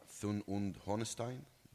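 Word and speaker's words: Thun und Hohenstein: As a politician, I allow myself, but I want to thank you, Rosa Thun und Honestein, this is the way to pronounce it, for the initiation of this very important, at least to us as Jews, as an Israelis, initiative.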